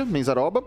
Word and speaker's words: Menzaroba: Nesta eleição ao Senado, o professor declarou 283 mil em bens na Justiça Eleitoral, como suplentes estão Gilson, Menzaroba, assim que pronuncia, Menzaroba, uhum.